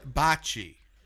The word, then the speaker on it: bocce: Bocce. Pronounce it correctly.